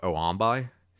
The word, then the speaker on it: Xombi: Oh, yeah, X-O-M-B-I? – Yeah, I didn't know how to pronounce it. Then you said Zombie. I was like, oh, I guess that's how it is. – I'm going with Zombie. It could be called Oombi, but I'm going Zombie.